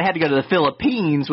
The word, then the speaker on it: Philippines: But they went to the Philistines and Am I saying that right? I feel like I'm Philippines. – Philippines, yeah. I was like, I'm not saying that right.